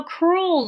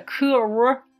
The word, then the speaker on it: cruel: この cruel の発音するポイントとしては、 cruel, cruel, cruel, cruel という R を C の後に cruel と発音することを意識さえしてもらえれば言えますし、 cool と言いたい時にはとにかくおを伸ばしましょう。